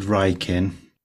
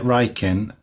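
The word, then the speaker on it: Wrykyn: I pronounce it Rykin, and that's the way I've always pronounced it in my head since I read My Gut Rykin when I was a kid, but I have no information as to how Woodhouse pronounced it.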